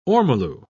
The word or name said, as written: ormolu